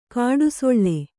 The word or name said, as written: ಗುಡಿಸಿಲು